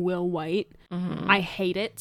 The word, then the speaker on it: Wight: So I, because it's spelled W-I-G-H-T. And so I'm afraid. – H-I-O. – It's spelled like right, like okay. right hand turn, but with a W.